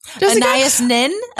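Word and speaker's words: Anaïs Nin: Anais Nin.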